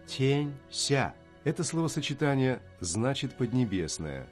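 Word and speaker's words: Ся (下): Ся, низкий, под чем-то, при чем-то, спускаться. На самом деле у этого слова много значений, и оно произносится в четвертом тоне.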